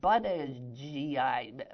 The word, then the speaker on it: Buttigieg: The first part of his name is Butt, literally, B-U-T-T, most unfortunate. But then it gets weirder. Buttigieg, B-U-T-T-I-G-I-E-G, Buttigieg.